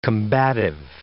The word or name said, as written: combative